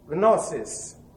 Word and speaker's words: gnosis: The one is the Greek word, G-N-O-S-I-S, gnosis. Remember, there are no silent letters in the Greek language. You say gnosis. Why? Because in English, the G and the N are pronounced simply as N.